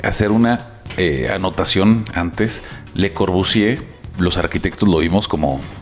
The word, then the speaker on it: Le Corbusier: Y este pues, es cotidiano para nosotros, pero para las personas que no son arquitectos, a lo mejor Le Corbusier, que se escribe con R al final, Le Corbusier.